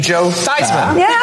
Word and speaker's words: Joe Theismann: I'm reminded of Joe Theismann, who when he wanted to win the Heisman, suddenly changed his name to Joe Theismann. Like that pretty changed pronunciation.